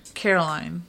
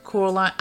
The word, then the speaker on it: Coraline: Some of my favorite movies are Caroline. – Coraline.